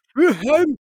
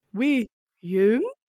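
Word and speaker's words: Willaume: He says it Frenchy, so it's like Wilhelm. That's German. Wilhelm.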